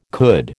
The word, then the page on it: could: American English